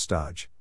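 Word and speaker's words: stage: But the cumulative effect is that drive-by contribution turns into staging, the chef word, pronounced stodge, for an unpaid apprenticeship at someone else's kitchen.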